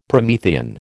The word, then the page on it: Promethean: American English